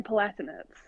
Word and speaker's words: Palatinates: who is back in the WBBL playing for the Durham Palinites, or Palinates, depending how you want to pronounce it, whether you're Latin speaking or not. She is the majestic Harriet Otwell-Silsby. Welcome to the MVP cast. – Hello, thank you for having me. – You're multilingual. How do you pronounce Durham's team name? – I say Durham Palatinates.